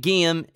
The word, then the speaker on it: Guilhem: My guest this week is Guilhem. It took me a long time to say that right because I am not a francophone.